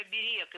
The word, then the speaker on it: оберег: Оберег, правильно. Но такая норма была зафиксирована недавно. До этого ударение следовало ставить на первый слог – оберег. А сейчас можно и так и эдак. И оберег, и оберег – теперь литературная норма.